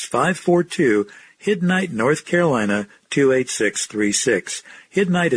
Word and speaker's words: Hiddenite: You've been listening to a message by Dr. Sam Vellini, senior pastor of the Freedom Baptist Church in Hiddenite, North Carolina.